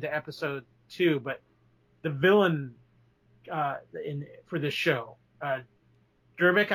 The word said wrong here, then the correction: Gravik: Gravik, yeah.